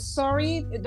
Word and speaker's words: Surrey: It, it, I think it's Surrey. It's Surrey. Uh, am I pronouncing it right? – Oh yes, sorry, yes, sorry, yeah.